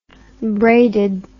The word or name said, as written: braided